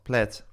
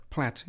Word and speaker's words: Plath: En die zei dat je in dit geval misschien zelfs de TH niet als een TH moest uitspreken. Dus nou ja, dat laat dat maar. – Sylvia Plath, Plath. – Plath, ja.